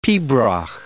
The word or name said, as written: pibroch